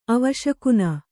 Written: ಅವಶಕುನ

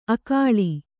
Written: ಅಕ್ಕುಲ

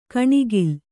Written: ಕಣೆಕಾಲ್

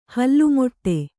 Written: ಹಲ್ಲುಮೊಟ್ಟೆ

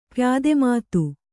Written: ಪ್ಯಾದೆಮಾತು

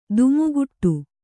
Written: ಧಿಮ್ಮಿಕಿಟ

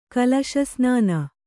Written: ಕಲಶಸ್ನಾನ

